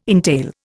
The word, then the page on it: entail: Indian